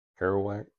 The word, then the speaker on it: Kerouac: You know, I've heard people say, I have a friend who says, there's a quote from some book, with, how do you pronounce that name? Jack Kerouac? – Kerouac.